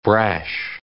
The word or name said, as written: brash